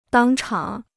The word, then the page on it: 当场: dāng chǎng